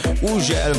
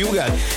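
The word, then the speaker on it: Hugel: Így van, az úriembereknek minden országban másképp ejtik a nevét, van ahol Ugel, van ahol Hügel, van ahol Ugel, meg mindenféle kép, de nálunk úgy mondjuk, hogy Ugel, és Salto és a Jamaican Bam Bam érkezik, csavarjátok fel a hangerőt.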